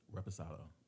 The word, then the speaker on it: Reposado: Clase Azul and the Reposado. However you say that. – Reposado. Come on, you close to Mexico. You should know how to say it.